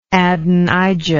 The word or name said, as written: adonijah